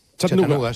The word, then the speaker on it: Chattanooga: ¿Se pronuncia así en Chattanooga? – Chattanooga. Sí.